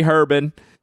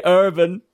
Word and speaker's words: Herbin: Do you want the Southern version or the pretend French version? J. Herbon, 1978, Kianit du Népal. – J. Herbon.